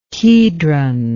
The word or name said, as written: kedron